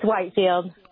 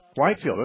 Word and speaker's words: Whitefield: Probably Whitfield. It's like George Whitfield, right? – No, it's Whitefield. – Whitefield, okay. It's spelled the same as Whitfield.